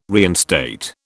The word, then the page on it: reinstate: British English